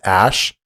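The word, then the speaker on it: Æ: Once the hardest part on there is the AE part. Once you know that it's pronounced Ash, it's really easy to pronounce X Ash A12.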